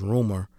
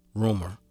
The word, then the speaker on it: RMR: And one project that I like, it's a guy, relatively new artist, his name is Rumor, but it's spelled RMR. – Rumor.